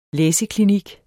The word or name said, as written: læseklinik